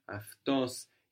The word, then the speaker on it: αυτός: And we mentioned as well before that αυτή, αυτός is spelled with AU, and that this combination of AU, or the Greek letters that look like A and U, is giving us αφ.